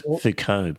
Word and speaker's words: Foucault: Well pronounced, Foucault, by the way, because we're going to be talking about French and German philosophers.